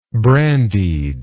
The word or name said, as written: brandied